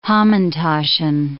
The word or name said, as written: hamantaschen